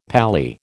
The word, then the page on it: paly: American English